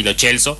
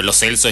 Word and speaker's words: Lo Celso: Digo Lo Celso porque él mismo lo pronunció así, acostumbrar también cómo se pronuncian los apellidos, acostumbrados a decir por ahí Lo Celso, pero Lo Celso es como lo pronuncia él, el propio jugador.